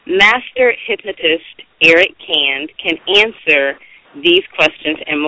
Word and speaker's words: Kand: Last name is pronounced Canned. So if anyone, if you see our Facebook page or our blog talk posts and it's spelled K-A-N-D, it's pronounced Canned.